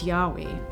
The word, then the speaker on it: Yahweh: Did you know that the consonants used in the spelling of God's sacred name, YHWH, pronounced Yahweh, are in fact the only consonants that, if correctly pronounced, do not allow you to use your tongue or close your lips?